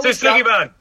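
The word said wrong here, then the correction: shou sugi ban: No, Shusugiban.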